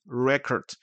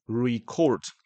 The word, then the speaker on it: record: 名词 record，动作 record。